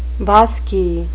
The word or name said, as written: bosky